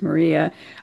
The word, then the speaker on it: Murraya: which is like Murraya. Doesn't look like Maria, but I think it's pronounced Maria.